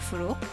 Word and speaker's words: Forough: Yes, my name is Fereshteh Furu.